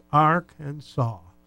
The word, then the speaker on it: Arkansas: Per the state code, the only acceptable pronunciation is in three syllables, Arkansas. – Arkansas? – The final S is silent, the A in each syllable with the Italian sound, and the accent on the first and last syllable.